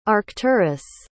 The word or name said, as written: arcturus